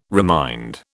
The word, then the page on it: remind: British English